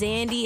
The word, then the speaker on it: Xandi: Again, my name is Zandy. It's spelled X-A-N-D-I. No Z. Started with an X. you can find me at Instagram at Zandy Official. X-A-N-D-I Official.